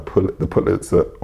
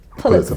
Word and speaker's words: Pulitzer: So there is a part in this book where the guy who wins the Pulitzer doesn't know how to pronounce it. He's been calling it Pulitzer. – Pulitzer. I think it's a Pulitzer. – I thought it was a Pulitzer. Well, I think I used to say Pulitzer.